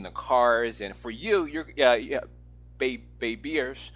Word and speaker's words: Babierge: So it's like baby plus concierge. Uh-huh. So it's BABIERGE. Uh-huh. And baby-ierge.